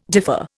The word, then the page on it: differ: Indian